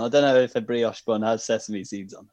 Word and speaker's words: brioche: Is it brochet? Is it a brochet bun? – Brioche. – A brioche bun.